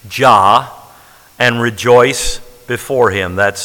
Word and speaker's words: Jah: Well, here's a word that only appears one time in the Bible, and that is God's name, Jah. Or they pronounce it many times, Yah, like in Yahweh. We say Jah as in Jehovah.